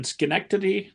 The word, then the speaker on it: Schenectady: Well, I had read that when you were young and you're living outside of I'm gonna mutilate this with my Indiana pronunciation, Schenectady, New York. – Perfect.